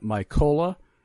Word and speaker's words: Mykola: Mykola, M as in Michael, Y-K-O-L-A, Mykola, which is the Ukrainian pronunciation for Nikolai, pronunciation and spelling. But M-Y-K-O-L-A, that's M as in Michael, Y-K-O-L-A, and you will see both of those audio links.